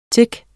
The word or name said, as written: tic